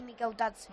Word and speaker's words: Mikautadze: Renato Veiga, Pape Gueye y Mikautadze. – Muy bien, bueno. Muy buena. – Bien pronunciado.